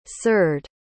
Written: ceard

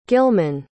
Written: gilman

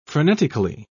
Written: frenetically

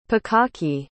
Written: pikake

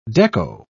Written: deco

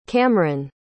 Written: camron

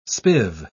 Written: spiv

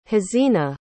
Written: hazina

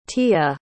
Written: tia

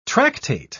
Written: tractate

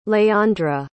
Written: léandre

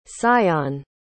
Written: sion

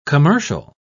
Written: commercial

